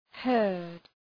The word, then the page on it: heard: hɜ:rd